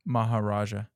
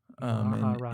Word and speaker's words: Maharaja: How do you say it? – Maharaja. It's a title. Maharaja.